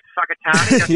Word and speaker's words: Whakatane: At Whakatane. – Yep. – And it's actually spelt with a WH. It's very weird.